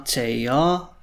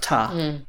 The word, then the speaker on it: tager: Jeg tænker et godt eksempel også, det er verbalet at tage, to take, which in present tense with an R, tager, but usually is pronounced tar. Jeg tager bussen. I'm taking the bus. But then you may hear somebody say, jeg tager bussen. But again, it's just sort of a more correct, clearer pronunciation, so neither of them are incorrect, they're both perfectly fine.